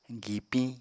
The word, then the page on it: gipiñ: Apatani